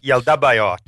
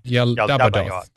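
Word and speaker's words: Yaldabaoth: Yal-Badaoth. Sorry, I'm mispronouncing that name. Yeah. – Yal-Dabaoth. – Yal-Dabaoth. That's right.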